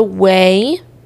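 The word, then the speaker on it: Ouai: And then I have the Way. That's how you say it right now.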